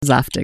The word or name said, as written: zaftig